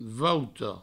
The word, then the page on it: vouta: vouto